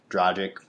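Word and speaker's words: Dragić: Goran Dragic, I can never pronounce his name right. – Dragic, whatever.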